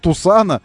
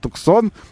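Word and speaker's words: Tucson: Есть еще, конечно, вопрос у меня по поводу Тусана или Туксон, вы называете его. Туксон, потому что пишется же Туксон, как будто бы, но называется Тусан, на самом деле.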